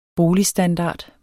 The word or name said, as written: boligstandard